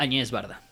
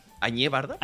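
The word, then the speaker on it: Agnès Varda: Doctor, usted me corrige, Agnes Barda. Añez Barda. – Añez Barda.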